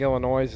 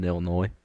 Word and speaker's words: Illinois: Did you just pronounce the S in Illinois? – Illinois. – Say it again. – Illinois. – One more time. Somebody. – First letter. First syllable. – Ill. Last. Noise.